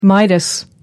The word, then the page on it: midas: American English